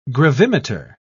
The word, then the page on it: gravimeter: British English